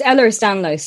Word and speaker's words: Ehlers-Danlos: Yeah, so it's Ehlers-Danlos syndrome, in short, EDS.